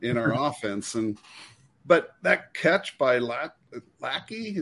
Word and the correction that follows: Lachey: is that how you say his name? – Lachey.